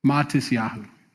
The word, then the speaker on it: Matisyahu: Matisyahu, yeah.